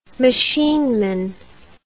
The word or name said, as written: machineman